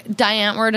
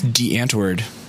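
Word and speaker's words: Die Antwoord: I think you said the name wrong. – I don't care. – It's D'Antwoord. Now you know. – Still don't care. – Well, you should start pronouncing it that way.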